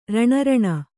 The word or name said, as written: ರಣಾರಣಿ